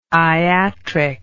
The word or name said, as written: iatric